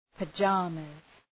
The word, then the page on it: pyjamas: pə’dʒɑ:məz